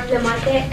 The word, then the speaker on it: Flemate: Gary Higgins, H-I-G-G-I-N-S, classification and parole representative. – Laura Flemate, F-L-E-M-A-T-E, office assistant. – Victor J. Castillo, C-A-S-T-I-L-L-O, assistant CMPR.